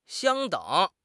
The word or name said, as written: 相等